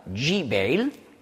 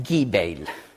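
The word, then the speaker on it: Jibail (جبيل): Tyrus, eine Stadt im Süden des Libanons, und Gebal, auf Arabisch sagt man Jibail, je nach Dialekt. In Ägypten sagt man Gibail. Ja, das G wird als G ausgesprochen.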